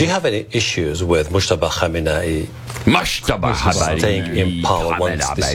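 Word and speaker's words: Mojtaba Khamenei: Okay, so it's Mujtaba Khamenei. Go back to the beginning. I got to get that pronunciation again. You got it. – That was beautiful.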